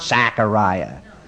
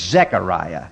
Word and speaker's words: Zechariah: It is not Zechariah. It's an E. It's Zechariah.